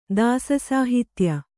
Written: ದಾಸಸಾಹಿತ್ಯ

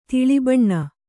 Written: ತಿಳಿಬಣ್ಣ

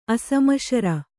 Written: ಅಶ್ಮಸಾರ

